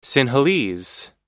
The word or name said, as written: sinhalese